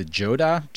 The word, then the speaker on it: Johdah: It's the the Johdah jacket, the J O H D A H.